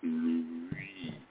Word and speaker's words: pluie: And pluie, which is rain, is a very difficult word to say, even for me, because it's pluie. You have to move from the I, the U to the IE. It's a very strange pronunciation.